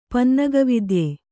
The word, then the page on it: ಪನ್ನಗವಿದ್ಯೆ: Kannada